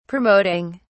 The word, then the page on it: promoting: British English